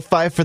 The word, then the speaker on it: Pfeiffer: Michelle Pfeiffer. – You don't think P. – Silent P.